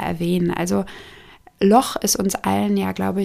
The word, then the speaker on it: Loch: Loch tatsächlich mit einem sehr kehligen Ch ausgesprochen, so wie in Bach, ist ein schottisch-gälisches Wort und wird im Englischen natürlich nicht so ausgesprochen. Also die Engländer würden eher vom Loch Ness sprechen, aber wenn wir von Schotten hören, dann sagen die tatsächlich Loch Ness.